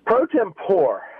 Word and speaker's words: pro tempore: Is it pro tempore or pro tempore? – It's pro tempore.